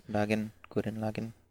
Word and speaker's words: Gurren Lagann: Is it Gurren Lagann? Help me out here. Yes. – Gurren Lagann.